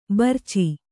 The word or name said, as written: ಭರ್ಚಿ